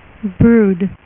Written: brood

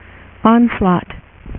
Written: Onslaught

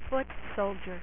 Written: Foot Soldier

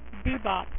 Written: bebop

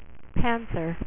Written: black panther

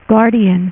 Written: Guardian

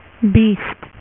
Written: beast

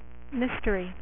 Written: Mysterio

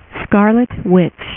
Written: Scarlet Witch

